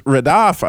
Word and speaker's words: radaph: And the word is radaph, I believe it's pronounced, R-A-D-A-P-H.